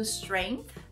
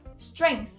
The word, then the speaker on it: strength: Aí aqui a gente tem a pronúncia do strength com a língua atrás dos dentes, strength, strength.